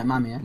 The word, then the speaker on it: Mamiya: How do you say it? Mamiya? – Mamiya? – I think it was Mamiya, wasn't it? – Yeah, Mamiya. – Near her room, there's all those ice, like, floored.